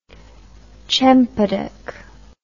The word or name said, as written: chempaduk